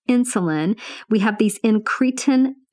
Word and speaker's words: incretin: I had to go look and figure out how to pronounce it. Incretin.